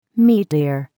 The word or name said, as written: meteor